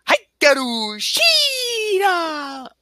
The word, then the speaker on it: Hikaru Shida: But anyway, by the way, you know how it's pronounced? It's like pronounced like this, like, Hikaru Shida.